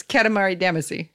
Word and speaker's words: Katamari Damacy: It's Katamari Damacy.